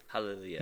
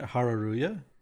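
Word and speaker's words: Hareruya: Well, it's Hare means like sun, and then Ya is like store, so it's like sun store, which is why their character is like a sun. But it sounds like Hallelujah.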